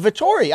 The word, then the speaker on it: Vettori: He controlled every element of that he took the heart out of the lion of Vettori. I mean, that's not wrong. – Yeah, you're absolutely right. And you said it correctly,